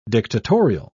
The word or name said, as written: dictatorial